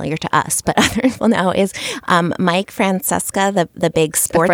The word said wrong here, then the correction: Francesa: Francesa, isn't it Francesa?